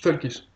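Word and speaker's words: völkisch: Et en fait, l'idéologie Volkisch, bon, Volk, ça veut dire Folky. – Volkisch. – Folkish. – Ouais, tu le prononces beaucoup mieux que moi.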